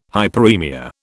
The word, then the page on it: hyperemia: British English